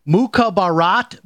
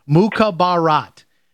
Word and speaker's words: Mukhabarat: The book is titled Mukhabarat Baby, My Life as a Wartime Spy for the CIA.